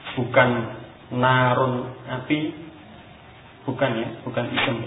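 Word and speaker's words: narun (نَارٌ): Walaupun tulisannya mungkin sama, tapi ini fi'il maka dibaca naro. Kalau isim narun api atau neraka itu narun, tulisannya sama. Tapi jenis katanya berbeda, yang satu isim yang fi'il, membacanya juga berbeda ya. Narun dengan tanwin dhamma, adapun naro ini fi'il, huruf akhirnya fathah, fi'il madhi.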